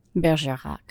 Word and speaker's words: Bergerac: Bergerac.